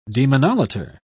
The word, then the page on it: demonolater: British English